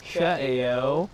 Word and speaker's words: Ka'eo: So my name is Ka'eo, and my last name is Yoshikawa. If you say Ka'eo, it means cornucopia, like stuffed with food in Hawaiian.